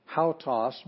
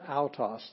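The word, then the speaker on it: autos (αὐτός): This is not hautos, but autos. There's no H sound because it's got a smooth breathing mark.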